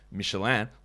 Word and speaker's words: Michelin: So that is why I would say Michelin, like Michelin for the Michelin guide, the Michelin stars, because it's a French company and that is how the name is pronounced.